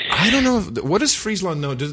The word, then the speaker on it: Friesland: Friesland.